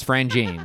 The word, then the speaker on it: Franjean: Fr- at first, when I read it, I said, is it Franjean? Like, I- Fra- No, it's, it's Franjean. like, that's how it's pronounced.